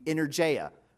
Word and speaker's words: energeia: And in Greek, they don't really have a soft G. They don't have a J sound or a G. So really, in English, we would understand this to be energeia. What does that sound like? Energy.